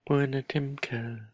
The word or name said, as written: puinatimcel